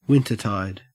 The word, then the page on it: wintertide: Australian English